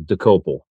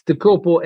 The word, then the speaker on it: Dokoupil: My ancestors would be very happy if I continued with the proper Czech, not Slovakian pronunciation of Dokoupil. – Dokoupil. – Yes. Yeah. It's Dokoupil, and it's a Czech word.